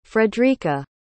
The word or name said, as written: fredricka